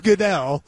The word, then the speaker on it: Goodell: Is that how you say it? – Yeah. It's Goodell.